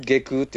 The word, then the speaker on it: 下宮: あれ、内宮じゃないですから。 – なんだっけ？ – 内宮って読みますから、うん、あれ。 – おお。 – その手紙ちゃんと調べるのがええで。 – 内宮下宮って読みますから。